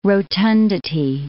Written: rotundity

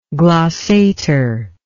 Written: glossist

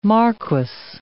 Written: marquess

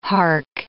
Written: hark

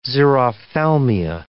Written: xerophthalmia

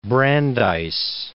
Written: Brandeis

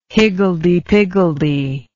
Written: higgledy-piggledy

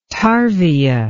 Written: tarvia

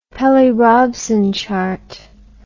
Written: Pelli-Robson chart

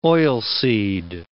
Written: oilseed

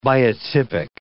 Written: biotypic

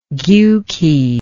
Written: giuki

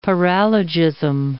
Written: paralogism

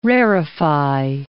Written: rarefy

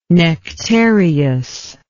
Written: nectareous

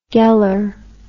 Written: geller

